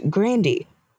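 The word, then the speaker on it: Grande: Ariana, I found out her last name is pronounced Grandy.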